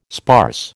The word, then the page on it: sparse: American English